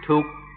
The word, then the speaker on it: dukkha: In Thai, they pronounce it tuk, tuk, and this is a word everybody knows.